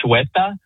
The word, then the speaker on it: Ceuta: It's the town of, and I believe I have the pronunciation here, Ceuta, C-U-E-T-A.